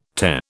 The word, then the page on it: Te: British English